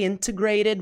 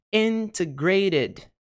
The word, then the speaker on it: integrated: Será que essa sílaba com T, ela é a sílaba tônica? Integrated. Integrated. Integrated.